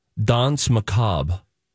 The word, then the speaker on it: Danse Macabre: Oh, I would say Danse Macabre. – Okay. – He nailed it.